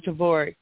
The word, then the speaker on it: Dvorak: And it's Becky Dvorak, I don't know how to say her last name, D-V-O-R-A-K, Dvorak. – Yeah, Dvorak. – Oh, do you know her? – Dvorak. No, but I know that name. I had friends that had that last name. It's Dvorak. It's pronounced. You did it right.